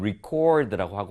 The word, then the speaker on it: record: 그래서 이렇게 같은 철자 record 동일한데도 불구하고 어떻냐면 이 품사에 따라서 동사일 때 명사일 때 발음이 다릅니다. 동사일 때는 record 뒤에 강세가 가고요. 그리고 명사일 때는 앞에 record라는 발음이 되는 것이죠.